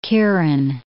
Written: Kieran

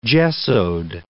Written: gessoed

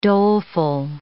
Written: doleful

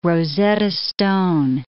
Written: Rosetta stone

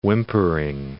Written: whimpering

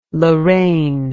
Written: loraine

